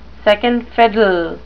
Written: second fiddle

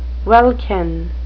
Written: welkin

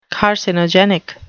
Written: carcinogenic

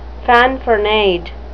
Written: fanfaronade

